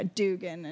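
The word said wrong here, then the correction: Heughan: and Hugan. Sam Hugan.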